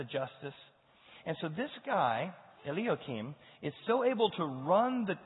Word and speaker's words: Eliakim: Eliakim.